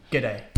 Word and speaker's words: g'day: It's a contraction of good day. – Yeah. – Just if you were ever wondering g'day. – That. – Yeah. But it's g'day. – Good day. – How do you spell that? – G. – Yep. – Apostrophe, is it? – Yep. – And then just the word day. So D-A-Y.